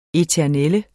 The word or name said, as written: eternel